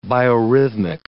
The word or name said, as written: biorhythmic